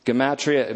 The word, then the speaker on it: gematria: Gematria, it may be pronounced gematria, G-E-M-A-T-R-I-A. Dropped my pen there, but let's just say G. Oh, I got another one. G-E-M, thank you, sir.